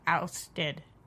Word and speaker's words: ousted: Just think of like, oh, you know, when we jousted that last time, and just take the J ousted. Sound away.